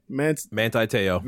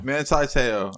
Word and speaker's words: Manti Te'o: How do you say this kid's name? – Manti Te'o. – Manti Te'o.